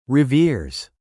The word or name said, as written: reveres